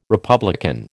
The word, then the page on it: republican: American English